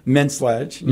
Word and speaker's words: Menslage: Menslage.